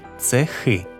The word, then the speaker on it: цехи: Насамперед відмітимо, що багато іменників вимовляються тепер із наголошеним закінченням «доми», «дуби», «цехи».